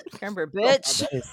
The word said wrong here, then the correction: Cumberbatch: My bad. – You're like Benedict Cumberbatch. – If I had to choose between Chris Pratt and Benedict Cumberbatch, I'd probably roll with him. – Say his name again. Say it one more time. That's Benedict, you're fucking terrible. Cumberbatch.